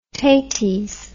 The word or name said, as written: taties